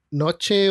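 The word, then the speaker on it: Knoche: El doctor Noch, que se escribe K-N-O-C-H-E, o sea, en Venezuela le, le llamaban así como en noche o canoche. – O knoch. – Knoch, claro. Pero en alemán se pronuncia Noch nació.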